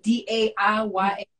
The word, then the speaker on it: Daiya: Daya, I might be saying that wrong, Daiya.